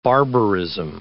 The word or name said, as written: barbarism